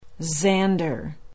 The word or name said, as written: zander